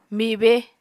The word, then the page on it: mibe: Apatani